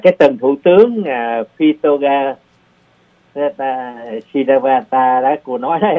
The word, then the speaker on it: Shinawatra: Thì cái tiếng Thái Lan á, cô thấy Shinawatra đó, nhiều người thật họ đọc Shinawatra. – Đọc là chỉ Sinawa, mà Hành Dung đọc thêm cái chữ phía sau nữa. – Đó, nó thấy chữ ra đó, nhưng mà không cần đọc cho đó Sinawa thôi.